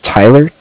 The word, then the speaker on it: Tyler: My name is Tyler, T-Y-L-E-R.